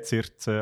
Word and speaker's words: Circe: Cersei, ja, wie schreibt man es? Mit C. C, Zirze. – Also. Zirze. – C-I-R-C-E. Äh, weil ich habe Cersei gehört und natürlich Shame denkt.